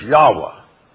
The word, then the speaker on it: Yahweh: But for the Germans, the W is pronounced like a V, so it couldn't be Jehovah or Yahweh, so it had to be Jehovah, Jehovah. And we get then Jehovah. And by coming through the German, we get Jehovah. Go behind the German, it's Yahweh. Yahweh.